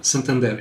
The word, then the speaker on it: Santander: We have Ticketmaster, obviously, owned by Live Nation, Santander, if I'm saying that right, a financial services. What was that? – Santander. It's a Spanish Santander.